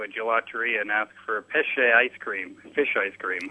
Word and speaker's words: pesce: Pesce and pesca. Pesce as in it's fish, and pesca would be peach.